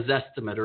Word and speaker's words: Zestimate: Because buyers are educated and they're looking online and they're looking at okay, for instance, with Zillow, a Zestimate or a Z-estimate. I've heard it pronounced both ways.